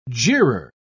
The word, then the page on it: jeerer: British English